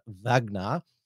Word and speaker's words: Wagner: The other more extreme psyop conspiracy is that the leader of the Russian paramilitary organization, the Wagner private military company, or it's pronounced in Russian Wagner.